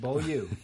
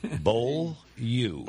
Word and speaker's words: Beaulieu: I cannot pronounce the name Beaulieu. – Beaulieu Vineyard. – Beaulieu. – Beaulieu. – Beaulieu translates into English as a beautiful place.